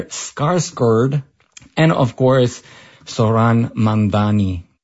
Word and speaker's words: Mamdani: The name is Mamdani, M-A-M-D-A-N-I. You should learn how to say it.